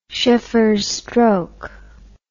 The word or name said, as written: sheffers stroke